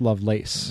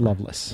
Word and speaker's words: Lovelace: Pronounced Lovelace, not Loveless.